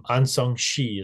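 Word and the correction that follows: Aung San Suu Kyi: is that right? – Ansang San Suu Kyi. – Suu Kyi, yeah. yeah. So it's like KYI, isn't it? – Yeah.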